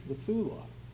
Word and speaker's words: bethulah: The first word is spelled B-E-T-H. U-L-A-H, Bethulah, Bethulah. The second word is spelled A-L-M-A-H, Alma, Alma.